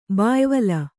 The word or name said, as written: ಬಾಯ್ವಲ